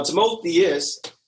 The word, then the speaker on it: Timotheus: So growing up, my pastor growing up used to always call me Timotheus. Now, Timotheus, correctly pronounced, is in the Bible. Basically, it's Timothy, but it's the Greek form of the name Timothy.